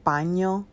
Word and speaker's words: paño: Um, my skin, when I get a lot of sun, my face like becomes really unevenly toned like, I get this thing called paño. That's how you say it in Spanish.